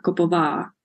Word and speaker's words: Koppova: Last one, the accent goes for the last syllable, so it's Lenka Koppova.